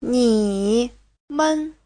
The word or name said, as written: ni3men5